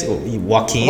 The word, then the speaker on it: Joaquin: I can always pronounce his name on Wycon Phoenix. Joaquin. – Joaquin.